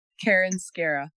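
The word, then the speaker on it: Karen Skarra: It's Karen Scara. – Karen Scara. Okay, awesome. Mm-hmm. And that's spelled K-A-R-E-N. Last name is S-K-A-R-R-A.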